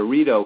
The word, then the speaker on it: Rideau: And if you do the true triangle, you go from Ontario, you go to Kingston, and you enter the Rideau Canal. It's pronounced Rideau, but it's spelled R-I-D-E-A-U. So you have a tendency to want to say Rideau, but everybody says Rideau.